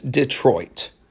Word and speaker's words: Detroit: So Detroit, I know some of us say Detroit, but I've learned that it's Detroit.